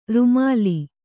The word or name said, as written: ರೂಮಾಲೆ